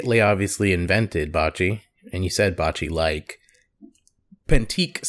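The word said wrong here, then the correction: pétanque: Sorry if I mispronounced it. Petanque. P-E-T-A-N-Q-U-E.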